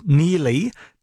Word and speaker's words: Neagley: Okay, so that's Neely, but is spelt Neegley. Neegley. Yes. So Neely, N-E-A-G-L-E-Y.